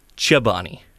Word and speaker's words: Chobani: And they say it right. It is Chobani.